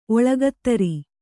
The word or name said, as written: ಒಳ್ಗದಿರ್